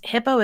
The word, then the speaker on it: Hippeaux: Hippo is spelled H-I-P-P-E-A-U-X.